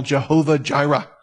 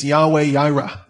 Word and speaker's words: Yahweh Jireh: Maybe you've heard Christians use that title, Jehovah Jireh. That is anglicized. Really, it's Yahweh Jireh. They don't pronounce it as a J.